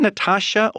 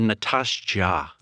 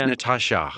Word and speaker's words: Nastassja: Is it Natasha or Natasha? – How should I say it? – It's the latter. – It's Natasha. Yeah.